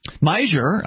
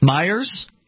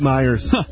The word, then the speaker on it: Meijer: Meijer's? Yep, that's where I grew up shopping in Ann Arbor. – There's not an S on that word. Okay. – We all called it Meijer's.